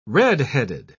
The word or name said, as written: redheaded